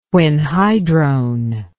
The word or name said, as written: quinhydrone